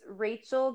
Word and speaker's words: Rachael: Oh, it's Rachel Going Rogue, but my name is spelled R-A-C-H-A-E-L.